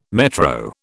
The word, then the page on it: metro: British English